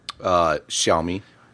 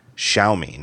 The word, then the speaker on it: Xiaomi: Xiaomi. – Xiaomi.